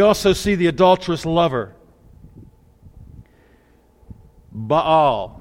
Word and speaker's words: Baal: Now see, some people wrongly pronounce this Baal. But you see, I personally know that it's not pronounced Baal in its singular form, nor Baals in its plural form. Okay, so I always make this emphasis. This is Baal and Baals.